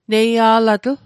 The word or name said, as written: neia'latl